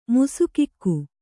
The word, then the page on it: ಮುಸುಕಿಕ್ಕು: kannada